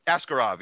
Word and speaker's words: Askarov: Askarov, right? – Askarov, yeah.